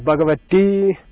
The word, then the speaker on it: Bhagavati: In Sanskrit, it's the long I, like Bhagavati.